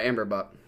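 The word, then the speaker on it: Amber Bock: This beer you brought me, this Michelob, is it Amberbach? Is that how you say it? Amberbach? – Let me look at it again. Yeah, Amberbach. Dark lager.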